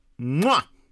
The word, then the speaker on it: Mwah: The mwah! Which was named after the sound commonly made when blowing a kiss. See, I pronounced it right.